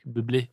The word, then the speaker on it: Bublé: Bublé, tror jeg, det er udtalt i virkeligheden. – Jeg elsker kanadisk – Det er fransk. Bublé.